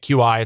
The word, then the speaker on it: Qi: If you've seen it, QI, it's pronounced Qi charger.